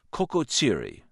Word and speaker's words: Kekexili: It's called Kokotsiri, that's spelled K-E-K-E-X-I-L-I, Mountain Patrol.